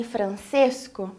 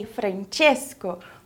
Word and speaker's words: Francesco: Você notou que eu não falei Francesco, mas eu falei Francesco?